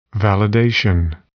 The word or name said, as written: validation